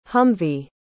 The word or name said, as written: humvee